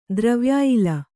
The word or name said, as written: ದ್ರವ್ಯಾಯಿಲ